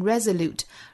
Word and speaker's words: resolute: Reassure，所以里面会有一个小小的 ye 的发音 reassure，and I want to reassure you，and I want to reassure you that 后面 if we remain united and resolute，remain 这个词后面音节要注意是双元音 a 再加鼻音 ain，if we remain united and resolute 这个词重音在第一个音节哈，要注意发音是 resolute。 Resolute，并且要注意这里面的字母 s 读成相对的浊音 z。